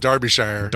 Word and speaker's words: Derbyshire: It's actually, believe it or not, pronounced Derbyshire.